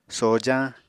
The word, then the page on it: sojañ: Apatani